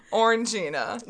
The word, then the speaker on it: Orangina: And I can't really say it right ever anymore. Orangina. – Orangina. – That's it.